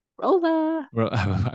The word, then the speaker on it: Rolla: That's R-O-L-L-A. – Rolla. – I was waiting for it. I knew it was coming. Is that like Holla? Yeah. It's spelled similar, just not with an H.